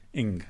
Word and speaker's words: Ng: How do you pronounce NG again as a first name? – Ing.